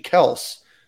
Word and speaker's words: Kelce: Their last name is actually Kels. And they thought it would be appropriate at the Super Bowl to say it's actually Kels and not Kelce.